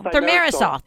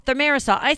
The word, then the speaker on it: Thimerosal: Thimerosal. – Thimerosal.